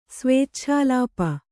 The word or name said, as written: ಸ್ವೇಚ್ಛಾಲಾಪ